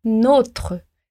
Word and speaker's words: nôtre: Como o nôtre faz referência ao nosso salário, a pronúncia é nôtre, com esse O fechado.